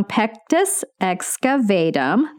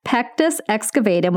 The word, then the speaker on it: pectus excavatum: So think about pectus as I'm going to say this wrong, pectus excavatum. There we go. I think I did it. Pectus excavatum, which is a concave depression in the chest wall.